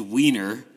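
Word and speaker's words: Weiner: One of our two Ottawa premieres this week, one is the political documentary, and I think it is just pronounced Wiener. – Yeah, Anthony Wiener.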